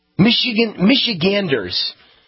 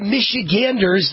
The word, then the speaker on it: Michiganders: What do they call them? Michiganlanders? Michiganders. A lot of Michiganders died.